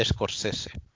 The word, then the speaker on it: Scorsese: Porque se dice Scorsese.